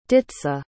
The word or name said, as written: ditza